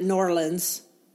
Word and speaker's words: New Orleans: So that kind of brings us to zones and how different your zone is from New Orleans, which, by the way, they pronounce it Norlands.